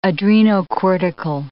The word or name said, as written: adrenocortical